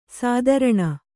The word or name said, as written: ಸ್ತ್ರೈಣ